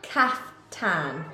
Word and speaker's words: kaftan: Kaft can. – No. Kaft. – Kaft. – Tan. – Tan. – Kaftan. – Kaftan. – Yes! That's the first time I've ever heard you say it right.